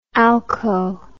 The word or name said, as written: alco